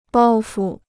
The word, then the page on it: 包袱: bāo fu